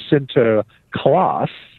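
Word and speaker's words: Sinterklaas: but he was pronounced something like Sint or Sinter Niklas. And you shorten Sinter Niklas to Sinter Klaas, and you are pretty close to what we recognize as Santa clause, the person who gives gifts, particularly to children.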